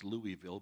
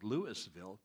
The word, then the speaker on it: Louisville: It's not pronounced Louisville because you're not in Kentucky anymore, dirt clod. It's called Louisville and Superior.